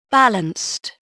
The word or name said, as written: balanced